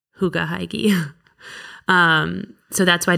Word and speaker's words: hygge: So, like, hygge. – Yes, hygge. – It's hygge. – Oh, it's hygge. – Yeah. It's spelled H-Y-G-G-E. – And it's pronounced hygge? – Yeah, I promise.